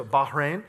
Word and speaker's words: Bahrain: And so the idea, if you've been to the Middle East, I've been to Bahrain, and the idea, were you impressed with the H that I did there? The Bahrain? Like Bernard, the H is silent, right? Am I right? Bernard, not Bernhard, right? So Bahrain.